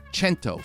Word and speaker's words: Cento: And the company is called Cento, C E N T O. It's C E N, pronounced ch, because it's Italian.